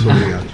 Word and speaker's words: obrigado: Obrigado. Oye, no me habéis dicho la pronunciación. – Muy bueno, es obrigado.